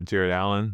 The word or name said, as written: Jarrett Allen